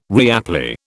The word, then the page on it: reapply: British English